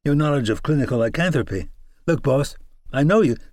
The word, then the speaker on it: lycanthropy: "Well, they've got you spouting off about the most detailed aspects of clinical lycanthropy.